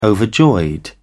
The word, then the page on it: overjoyed: British English